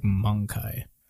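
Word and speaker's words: Möngke: M-O-N-G-K-E. Sounds like Mongkai.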